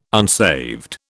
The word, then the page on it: unsaved: British English